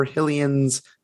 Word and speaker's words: Hylian's: Uh, they ask, are those rubies Link keeps finding in jars just poor Hylian's life savings hidden away? – Yeah.